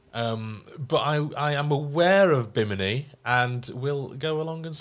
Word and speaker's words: Bimini: Bimini? – Yeah, Bimini.